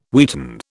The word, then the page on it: wheaten: British English